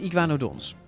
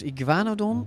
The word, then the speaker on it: iguanodon: Ja, wij zouden volgens de Nederlandse klemtoon als iguanodon moeten zeggen.